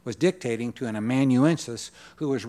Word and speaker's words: amanuensis: How do you say that, Rodney? Thank you. Amanuensis, or we would call a scribe.